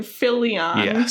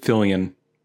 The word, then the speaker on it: Fillion: I've always heard Fillion? It just Fillion. – Nathan Fillion. Fillion. Oh, that makes sense.